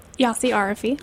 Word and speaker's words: Yossy Arefi: Yossy Arefi.